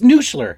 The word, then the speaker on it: Nussler: The Nussler. – Is that how it's pronounced? – It's Nussler.